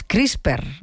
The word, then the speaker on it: CRISPR: Todo lo que tiene que ver con esto de las CRISPR, no sé si lo digo bien, ¿no? – Sí.